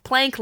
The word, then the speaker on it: Planck: The smallest unit of length in the universe is what's known as a Planck length. Plank length. – You said that right.